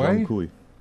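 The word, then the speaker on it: Zhong Kui: It'll be Black Myth, I don't know how to pronounce it, Zhang Kui? – Zhang Kui.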